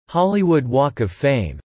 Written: Hollywood Walk of Fame

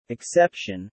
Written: exception

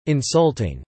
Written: insulting

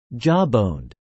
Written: jawboned

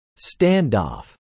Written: standoff

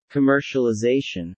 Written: commercialization

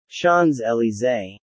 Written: Champs-Elysees

